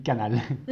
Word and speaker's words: Knal: Et j'ai été plus particulièrement au food market du Knoll. – Du Knoll? – Du canal. Ça s'écrit K-N-A-L.